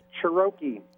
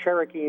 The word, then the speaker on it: Cirachi: I'm very close. It's Cherokee. Ah. It's Cherokee Indian, only with a slightly different accent.